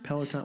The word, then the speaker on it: Peloton: Pendleton bike that's $3,000 and tell you how it works. Unless Pendleton. – Are you talking Peloton? – Peloton, whatever. – You got to say it right, man. Peloton.